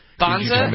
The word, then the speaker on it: Banza: And then you gave me something like Banda. I haven't found it yet. – Banza? Yeah, that's Banza. Awesome. Yeah, B-A-N-Z-A.